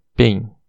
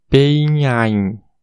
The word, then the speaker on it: pain: Uh, então, ó, só para deixar claro o conceito de dígrafo, observe a palavra pen, P-A-I-N, pen, a pronúncia correta é pen, mas se a gente fosse pronunciar o A e o I separadamente em inglês, ficaria peinhain, ain tá bom? Porque o A, a pronúncia correta é EI e a pronúncia do I é AI. Então ficaria pe-ain, né?